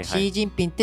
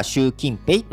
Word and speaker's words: 習近平: はいはいはい、シー・ジンピンって中国語では読むけど、あの日本語では習近平って発音するじゃない、